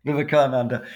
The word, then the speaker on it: Vivekananda: was Swami Vivekananda. I'm sure I'm mispronouncing all these terms, by the way. – No, that's right. – But could you tell everyone a little bit about Swami Vivekananda? – Vivekananda.